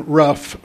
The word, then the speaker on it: Ruff: But if you can write down my email address, my name is Adam Ruff, first of all, and the last name is spelled R-U-F-F.